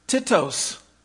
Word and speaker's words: Titus: So, Titus, the book of Titus. Actually, in the Greek, pronounced Titos, if you can believe it.